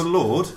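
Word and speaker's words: Lorde: Is it Lord? – Yeah, that's one point. – Lordy, or how do you say it? – I think it's Lord.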